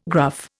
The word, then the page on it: gruff: Indian